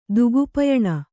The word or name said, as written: ದುಗುಪಯಣ